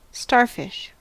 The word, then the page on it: starfish: American English